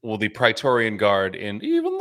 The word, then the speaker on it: Praetorian: Praetorian, yeah.